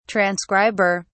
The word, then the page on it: transcriber: British English